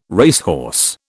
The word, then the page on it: racehorse: British English